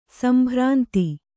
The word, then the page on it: ಸಂಭ್ರಾಂತಿ: kannada; sambhrānti